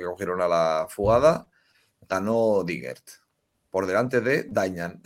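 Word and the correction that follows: Deignan: Es Deinan, pero ya dijo Dayan.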